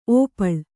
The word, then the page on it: ಔಪಲ: Kannada